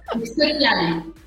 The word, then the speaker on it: Piali: It is called Piali. Did I say it right? – Yes. Amazing. Yes. Piali.